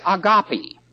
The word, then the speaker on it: agape: Now, the first one is agape. And it's also spelled and pronounced agapo, A-G-A-P-E or A-G-A-P-A-O, agape or agapo.